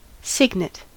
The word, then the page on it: cygnet: American English